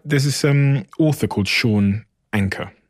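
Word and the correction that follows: Achor: Oh, Sean Aker, yeah, from Harvard. – Butcher his name. – Yeah, Aker.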